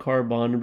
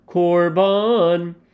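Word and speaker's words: korban (קרבן): It looks like karban, but it's pronounced korban.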